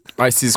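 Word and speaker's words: ICES: ISIS cream ISIS. ISIS. It's the International Cake Exploration Societe, their pronunciation with the accent.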